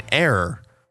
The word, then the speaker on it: heir: So we say an honor, an hour, or an heir. Like an heir to the throne. For example, because the initial letter H in all three words is not actually pronounced.